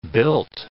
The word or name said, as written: built